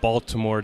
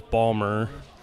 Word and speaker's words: Baltimore: First off, I want to correct Dana, and since I'm a native of the area, it's not Baltimore, it's Balmer. So that's the correct pronunciation.